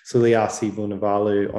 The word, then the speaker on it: Suliasi Vunivalu: Um, will be around the, the traps, but I think you put in Marika and um, Suli Villavano. I can't even say his name. Suliasi Vunivalu on the other wing.